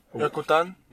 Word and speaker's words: Yucatan: How do you pronounce it? – Yokotania. – Yucatan? – Yucatan? – Yeah. I was so fucking close, dog, huh? Isn't it? – Yeah. – Yucatan, right? – Yo, dog, but these kids with their fucking...